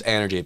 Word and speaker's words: ENRG: But it's like energy, it's like a pun. – Yeah. – It's pronounced energy, but it's spelled ENRG.